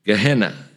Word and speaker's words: Gehenna: How would we pronounce it? Gehenna. Gehenna without an M.